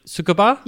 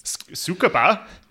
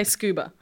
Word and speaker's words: Tsukuba: A lot of people say scuba. – Yeah, scuba. That's what I thought it was. Like scuba. – Yeah, it's spelled with a T, so it's not.